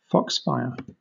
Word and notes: foxfire: British English

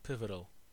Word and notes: pivotal: American English

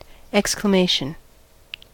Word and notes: exclamation: American English

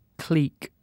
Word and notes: clique: British English

